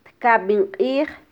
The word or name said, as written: tkabin qij